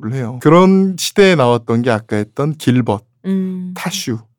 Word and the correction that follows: 드슈: 아, 타슈가 아니죠. 드슈. – 드슈. – 이런 술들이에요. – 그렇게 발음 안 될 것 같아요.